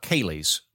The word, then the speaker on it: Ceilidh: Spelled like Celia Deed or something. It's a very weird looking Celtic word. It was pronounced Ceilidh, and it's a straight up Scottish barn dance.